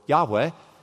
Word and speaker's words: Yahweh: And the Lord, or Yahweh, as we think it should be pronounced, is Christ, because that is stated explicitly in the New Testament on many occasions.